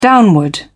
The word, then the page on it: downward: British English